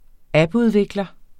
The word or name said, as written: appudvikler